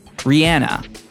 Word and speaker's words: Rihanna: Rihanna.